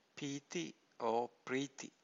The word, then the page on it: piti: Pali